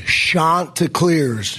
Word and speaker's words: Chanticleers: Chanticleers. Chanticleers. Not Chanticleers. Chanticleers. – Got that, everybody? When you are watching a team who's now one game away from the World Series, Final Series, Championship Series, know how to say their name. What's that again? I've already forgotten. Chanticleers. Like Shasta.